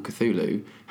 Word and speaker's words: Cthulhu: If you hadn't known it's called Cthulhu, how would you pronounce that? – Kufu.